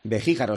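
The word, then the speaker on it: Begíjar: Beguijar, o sea, es las dos con J, complicado. – ¿No? – Complicado. – Beguijar, GJ, pero se pronuncia Beguijar, vale.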